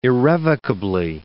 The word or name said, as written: irrevocably